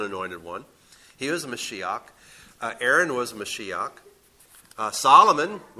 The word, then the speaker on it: Mashiach: Or better yet, Mashiach. Mashiach is how it's actually spelled or pronounced if you were reading it in Hebrew.